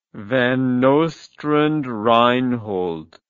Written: Van Nostrand Reinhold

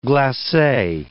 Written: glace

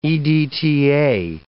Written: EDTA

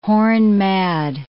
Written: horn-mad

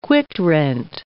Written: quitrent

